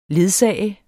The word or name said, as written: ledsager